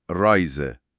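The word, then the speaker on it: Reise: بله، اسمش ار هست، تلفظش آلمانیه و با فارسی و انگلیسی فرق داره. معمولا از ته حلق میاد، یه چیزی نزدیک به ق، خیلی خفیف، مخصوصا اول کلمه. – مثل؟ – مثلا رایزه، سفر.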